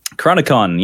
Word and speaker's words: Chronicon: But yeah, so Chronicron, am I saying that right? – Chronicon, yes.